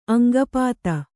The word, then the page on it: ಅಂಗಪಾತ: aŋgapāta